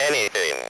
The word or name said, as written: anything